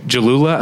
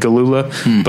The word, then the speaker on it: Jalula: You've got a younger band featuring, two great young actresses, Cara Delevingne and Dylan Jalula. Apparently it's a soft G. I've been saying Galula,